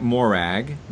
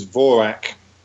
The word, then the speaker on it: Morag: It's a Gaelic word. I mean, we would pronounce it Morag, and Morag is now a fairly familiar Scottish girl's name, actually. But the correct Gaelic pronunciation is Vorak.